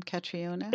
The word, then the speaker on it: Catriona: Catriona, is that how you say her name, Catriona? – Yes.